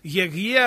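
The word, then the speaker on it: Егье (אֶהְיֶה): Буквально оно произносится так: Егье ашер Егье, я есть тот, кто я есть.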